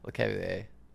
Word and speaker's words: Lecavalier: Jamie Benn or Vincent LeCavalier? Is that how you say it? – Yeah, LeCavalier. – LeCavalier? – Yeah.